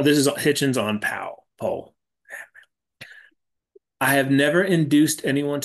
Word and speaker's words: Powell: He's the reason I know to pronounce it Paul and not Powell. – Yeah, if you're wondering, it's spelled, it looks like Pal, but it's Paul.